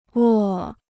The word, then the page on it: 卧: wò